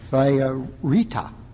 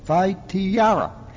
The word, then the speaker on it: Thyatira: She was from the city of Thyatira. I'm sorry, I said that wrong. Thyatira.